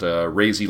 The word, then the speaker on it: Razy: He said that's pronounced Razy like crazy, so I know we were having some issues with that last time.